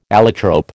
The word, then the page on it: allotrope: American English